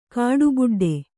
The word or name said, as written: ಕಾಡುಬುಡ್ಡೆ